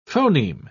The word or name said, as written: phoneme